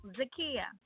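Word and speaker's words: Zakiyyah: Zakiya. – Zakiya. How you spell that, Zakiya? – Z as in zebra, A-K-I-Y-Y-A-H.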